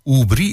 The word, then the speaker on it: Aubry: En Ursula Aubry, of Ursula Oubry, ik geloof dat ik het op zijn Frans moet uitspreken.